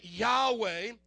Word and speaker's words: Yahweh: It's the four Hebrew letters, YHWH, Yohevah, and we pronounce it Yahweh.